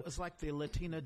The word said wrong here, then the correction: Lectio: Divina. Am I saying it right? – Lectio Divina, yeah.